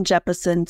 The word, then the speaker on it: Jeppesen: And I don't know that I said the name right. Is it Captain Jeppesen? – It is. It's Captain Jeppesen.